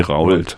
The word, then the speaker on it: Rault: Wie schreibt man Rold? – R-A-U-L-T, wie Rault.